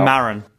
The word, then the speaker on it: Maron: I got confused at the start because I always get confused between Nerdist and then that one with Mark Maron or Maron. How do you say his name? – Oh, WTF. – Yeah. How do you pronounce his surname? Come on, help me out. – Maron.